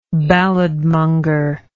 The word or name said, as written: balladmongering